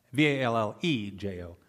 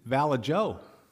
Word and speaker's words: Vallejo: It was actually the words that he was reading was Vallejo, Vallejo in Spanish. But he pronounced it Valla Jo.